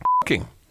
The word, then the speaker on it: Fuxing: It was also called, this is spelled F-U-X-I-N-G. – Fucking. – Fuxing. Husbands who committed adultery were punished with castration as required under this law.